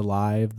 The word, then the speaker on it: LIV: Okay, is it Live? – Yeah, that's how they say it. – Okay, I didn't know if they said the LIV, the live, the live.